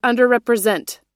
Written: underrepresent

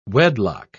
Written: wedlock

